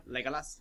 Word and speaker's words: Legolas: Freaking Legolas, right? Did I say that right? – Legolas.